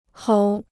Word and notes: 吼: hǒu

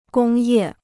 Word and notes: 工业: gōng yè

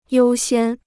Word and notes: 优先: yōu xiān

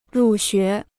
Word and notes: 入学: rù xué

